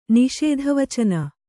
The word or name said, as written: ನಿಷೇಧವಚನ